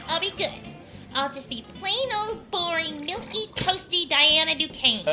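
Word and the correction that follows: milquetoast: I think it's actually milk toast.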